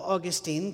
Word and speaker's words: Augustine: And then by the time of Augustine, who most of you would call Augustine, because that's the way your teachers pronounce it,